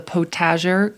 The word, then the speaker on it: potager: I love I believe it's called a potager garden.